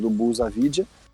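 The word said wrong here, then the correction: Avdija: Avidija, né? Na verdade, essa é a pronúncia correta, Avidija.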